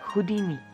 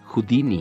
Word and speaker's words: Houdini: Houdini, ¿cómo se pronuncia? – Houdini. – Houdini, uh-huh. De Houdini.